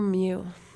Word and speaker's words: Mew: Look out for Mew. – And a band. – It's like a cat sound. – No. – Mew, that's how you spell it. M-E-W.